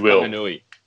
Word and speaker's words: Whanganui: I've been saying it wrong the whole time. So that, I reckon, we'll need Wax333 to confirm that. But I reckon it's Whanganui. Whanganui. Yeah.